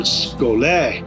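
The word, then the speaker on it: skole (σχολή): School is from the Greek word skole, pronounced skole, which in the New Testament only occurs in Acts 19:9, where it's translated lecture hall, namely the lecture hall of Tyrannus.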